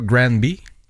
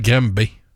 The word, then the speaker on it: Granby: D'ailleurs, y a-tu quelqu'un qui veut m'expliquer pourquoi on prononce pas ça Granby? – C'est parce que ça s'appelle Grimbé. Ouais, je suis né à Grimbé. Je suis pas né à Grimbé. – Ben, quand tu lis ça, c'est Granby, il me semble, non? – C'est Grimbé. On est à Grimbé.